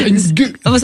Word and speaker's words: Ng: C'est comme ça que ça se prononce. Ng.